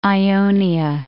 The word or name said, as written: Ionia